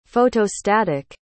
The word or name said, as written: photostatic